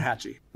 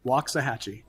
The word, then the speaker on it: Waxahachie: We learned from a native Texan how to pronounce what city correctly? – Waxahachie. Waxahachie. – Waxahachie. It's not even spelled the way you think it is.